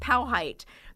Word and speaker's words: Powhite: The debate resurfaced again in 2001 when a billboard went up on Interstate 195 near Broad Street that said Powhite is pronounced Powhite.